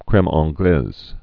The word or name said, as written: creme anglaise